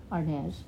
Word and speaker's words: Arnaz: Arnaz. Yeah. There's more of an S sound. Uh huh. Well, that's that's Spanish.